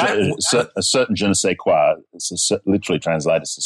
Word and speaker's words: je ne sais quoi: Je ne sais quoi.